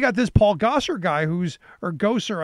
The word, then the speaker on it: Gosar: Gosar is how I've been told his name is pronounced, as if he is that creepy dude in the Ghostbusters movie. Spelled differently, pronounced the same.